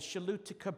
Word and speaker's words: Choluteca: Okay, it's spelled the C-H-O-L-U-T-E-C-A, the Shalutica Bridge.